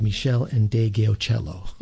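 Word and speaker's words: Meshell Ndegeocello: And I thank Brian Saunders for the correct pronunciation of Michelle and De Geocello.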